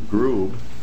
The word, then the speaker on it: Grube: My name is Jim Grube. That's spelled G-R-U-B as in boy E.